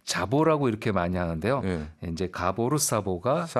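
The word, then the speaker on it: Gábor Szabó: 그 사보, 아, 스자보는 우리식 발음이고요. 그 가보, 사보, 가보로 이렇게 얘기한다고 합니다. 반대로 얘기하기도 하고요. 이제 가보로 사보가 맞는 거죠.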